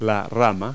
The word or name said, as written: branch